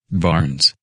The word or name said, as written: Barnes